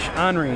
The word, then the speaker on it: Henri: Well, actually, my name itself is French, Henri.